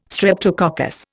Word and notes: streptococcus: Indian

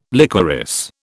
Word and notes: liquorice: British English

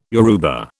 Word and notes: Yoruba: British English

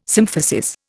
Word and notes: symphysis: Indian

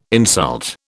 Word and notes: insult: British English

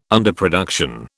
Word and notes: underproduction: British English

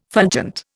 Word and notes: fulgent: Indian

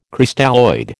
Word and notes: Crystalloid: American English

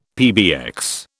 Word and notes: PBX: British English

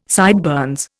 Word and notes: sideburns: Indian

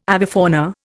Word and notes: avifauna: Indian

